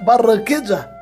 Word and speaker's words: Barranquilla: Com pronúncia correta aqui na América Latina é Barranquilla, mas depende da região também. Na, na Catalunha seria Barrancóquia.